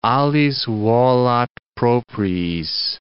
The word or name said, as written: alis volat propriis